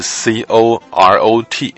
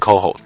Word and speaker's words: CoRoT: COHO，对，COHO 是法文的发音呢、啊。其实英文看的话是 CORROT。 – Corot。这每个人一定这样。 – 对对对，美国人念的是非常不标准的啊。法国人念 COHO。那 COHO 呢，其实很大一个任务就是要寻找恒星啊周遭它的行星经过了恒星表面所造成瞬间的光度亮度降低的。